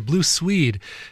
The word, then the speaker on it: Blue Swede: Yes, originally by Cher covered there by Blue Suede, or I'm sorry, Blue Swede is how they pronounce that.